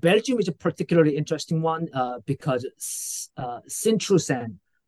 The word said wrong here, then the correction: Sint-Truiden: uh, guy, let me know if my mm pronunciation is incorrect. – Uh, Sint-Truiden. Sint-Truiden.